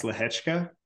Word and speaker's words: Lehecka: Tsitsipas Lehecka hope hope I didn't butcher it, uh, but that's that's how I've been saying it. – That's how you say it. I looked yeah. it up before.